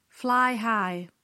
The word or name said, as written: fly high